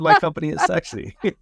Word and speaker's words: Cixi: Another one, Julia, actually, from when I was at Eurobike, I got to meet a company called, I think you pronounce it, Sexy. CIXI.